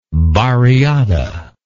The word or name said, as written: barriada